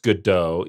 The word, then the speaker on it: Godot: Am I saying that right? Godot? – I think it is pronounced Godot, even though my American brain pronounced it Godot forever.